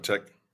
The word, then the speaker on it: Apotheke: Was it Apotheke? Is that how they pronounce it? – Apotheke. – Apotheke, yeah. Yeah.